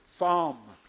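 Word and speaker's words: thom (תם): That's the Hebrew word perfect, pronounced thom.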